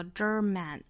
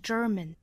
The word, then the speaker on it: German: 首先，我们来看第一个词 German，里面含有字母 R，所以我们说要卷舌是 German。后面的这个大家不要读成了 German 啊，应该是 German。也就是里面这个元音是一个中元音，是弱读音。